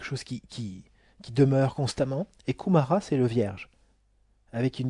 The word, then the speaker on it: Kumara: Sanat, S-A-N-A-T, Kumara. – Kumara, ça se prononce Kumara.